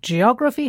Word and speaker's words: geography: Now, geography has four syllables. G-O-G-R-A-P-H-Y. And the stress falls on the third syllable from the end. Geography. Not geography, geography or geography. It's geography. – Words that end in the letters P-H-Y, pronounced phi, usually have this stress pattern, like biography and photography.